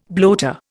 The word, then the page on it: bloater: Indian